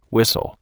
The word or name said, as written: whistle